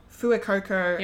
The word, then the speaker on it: Fuecoco: Fuecoco yep.